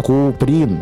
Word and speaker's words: Куприн: Його чомусь часто називали Куприн. Тоді він підскакував до вкривдника і, схопивши його за фалди піджака, майже по складах вимовляв: «Я Куприн, і всякого прошу це пам'ятати. На їжака сідати без штанів не раджу».